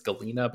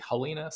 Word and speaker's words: Halina: She was from the Ukraine originally, and I've seen conflicting reports on how you pronounce that first name. Evidently in Russian, it's Galina, but in Ukrainian, it's actually Halina.